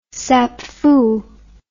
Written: sapfu

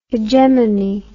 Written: hegemone

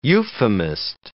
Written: euphemist

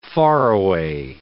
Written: faraway

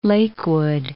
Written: Lakewood

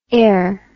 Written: aer

